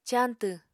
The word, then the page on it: cañtw: apatani